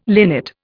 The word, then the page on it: linnet: Indian